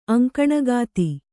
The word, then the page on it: ಅಂಕಣಗಾತಿ: kannada